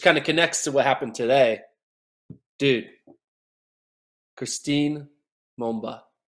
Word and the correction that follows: Mboma: I said her name wrong. Christine Mboma. Mboma? How do I say her last name? – Yeah, I think it's Mboma.